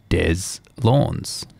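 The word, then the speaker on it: Deslondes: is pronounced Des Lawns. Rhymes with fawns.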